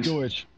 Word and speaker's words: Doge: How do you Doge. – Dogecoin. – Doge.